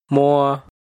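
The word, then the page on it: Muas: Hmong; last name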